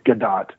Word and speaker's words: Gadot: It's Gadot. – Is it Gadot? Is that how she pronounces it? – Yeah, it's Gadot. She's Israeli, and the OT is a plural. It's pronounced.